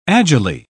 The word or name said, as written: agilely